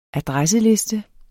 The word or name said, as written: adresseliste